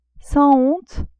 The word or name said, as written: sans honte